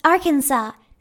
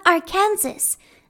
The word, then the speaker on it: Arkansas: They are two different states, but it's actually pronounced Arkansas, not Arkansas.